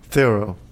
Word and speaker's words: Theroux: Theroux. – It's probably a French name, so it's got an X in the end, but you don't pronounce it.